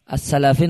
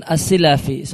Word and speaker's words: As-Silafi: Kemudian Hakka Al-Imad Al-Asbahani ketika menjelaskan biografi seorang hamba yang saleh Al-Hafidh Abu Thahir As-Silafi, bacanya bukan As-Salafi namun As-Silafi, seorang ulama pakar hadis di masa silam, Abu Thahir As-Silafi rahimallahu, beliau mengatakan qala Ar-Rahawi.